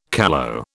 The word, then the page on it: callow: British English